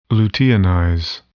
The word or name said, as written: luteinize